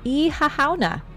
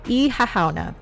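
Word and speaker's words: Oíche Shamhna: Ihahauna. See, now you understand why I go, oh dear God. Because it's not really spelled the way it's pronounced like at all. So Ihahauna, and celebrates the mating between Tuatha De Danann gods Dagda and the river Unis.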